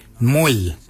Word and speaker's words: mooly: Warrigal is spelled W-O-R-R-A-G-O-L, Warrigal. Warrigal is your upper mouth. For your nose, we say Mwil. Mwil is your nose. Mwil is spelled M-O-O-L-Y, Mwil.